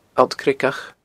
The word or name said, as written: alltcreagach